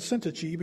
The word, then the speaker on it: Syntyche: And according to the Bible pronunciation guide website that I looked this up on, their names were Euodia and Syntyche, because I had no idea how to say either one of those.